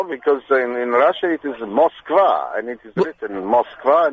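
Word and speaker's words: Moskva (Москва): We should call place names the way they're pronounced in the place. It should be Paris. It should be Newcastle. It should be Moskva.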